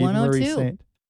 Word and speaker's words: Eva Marie Saint: Eva Marie Saint. – You got it. 102. – Eva Marie Saint.